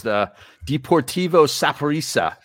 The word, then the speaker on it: Deportivo Saprissa: Of course, tonight on television, the Union against Deportivo Saprissa.